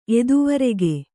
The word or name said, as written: ಎದುವರೆಗೆ